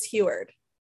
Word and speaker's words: Heward: In English, it's Heward. It's French Italian. Nobody can pronounce it.